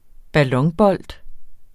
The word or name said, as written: ballonbold